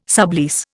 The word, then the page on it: sublease: Indian